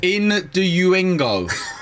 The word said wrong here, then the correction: Innuendo: So close! – In-du-ingo. – Innuendo. – Innuendo. – Yes. – Yeah, got it right.